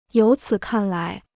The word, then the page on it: 由此看来: yóu cǐ kàn lái